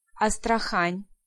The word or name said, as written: Astrakhan